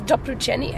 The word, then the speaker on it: doporučení: And I got a note that says, what is doporučení? How do you say that? – Doporučení, a recommendation.